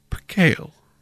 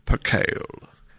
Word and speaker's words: percale: the word is Percale. Percale. And